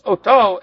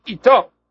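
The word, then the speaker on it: oto (אותו): Aleph tuf vav, it's pronounced oto as if there's a vav in there, malay, but it's spelled in a way that would say ito.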